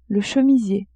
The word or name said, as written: chemisier